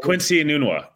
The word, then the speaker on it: Quincy Enunwa: Quincy Enunwa.